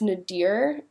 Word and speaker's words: nadir: I believe it's pronounced nadir, N A D I R.